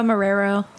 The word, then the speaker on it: Marrero: Did I pronounce that right? – Marrero. – That's close. That's okay. Close.